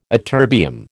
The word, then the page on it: ytterbium: American English